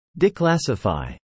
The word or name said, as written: declassify